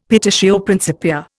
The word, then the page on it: petitio principia: Indian